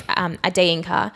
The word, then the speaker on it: Adeyinka: My last name is Adeyinka, and the E is like the E in a cafe. That's how you say it in like a Yoruba accent or a Nigerian accent.